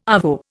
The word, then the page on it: arvo: Indian